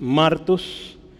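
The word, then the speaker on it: Martus (μάρτυς): Hay un acento en la A, entonces es Martus.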